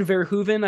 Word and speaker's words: Verhoeven: I've heard Verhoeven. I think that's like the Dutch pronunciation, but with whom Verhoeven discussed the original novel by Robert Heinlein in depth.